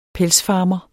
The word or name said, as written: pelsfarmer